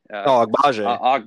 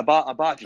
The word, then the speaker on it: Agbaji: Agbaji. – Agbaji. Yeah, Agbaji.